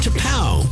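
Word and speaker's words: T'Pau: T-apostrophe-P-A-U, pronounced T-Pow.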